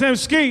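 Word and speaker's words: Emskee: Beat Miners Radio, my name is M Ski.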